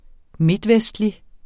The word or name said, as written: midtvestlig